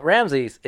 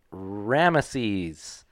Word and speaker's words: Ramses: Well, Ramses is also often pronounced Ramesses. – It is. – Ramuse, Ramusa, Remumasi. – Who pronounces all these differently? – It's just because nobody has any fucking idea how it's pronounced. – Right. – They have an idea of how, generally, so Ramses is just the easiest way to say it.